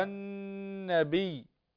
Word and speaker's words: النبي: يا أيها يا أيها مباشرة يا أيها النبي مباشرة تنتقل للنون اللام لا تنطق هنا لا نقول يا أيها النبي خطأ يا أيها النبي هكذا.